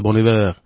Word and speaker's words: Bon Iver: Franču valodā būtu jāraksta nevis Bon Iver, bet Bon Hiver, bet izrunājas kā Bon Iver.